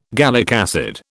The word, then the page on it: gallic acid: British English